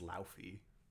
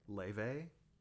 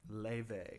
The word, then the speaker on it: Laufey: She, I always thought her name was Laufey. – Laufey? – Yeah, look at how it's spelled. – What is it? – Leve. – Jeez. – She's from Iceland.